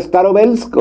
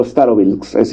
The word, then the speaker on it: Starobilsk: Lo que estamos viendo al fondo ocurrió en Lugansk, concretamente en un pequeño poblado llamado Starobilsk. Se puede pronunciar Starobelsk o Starobilsk, digamos, en español es indistinto,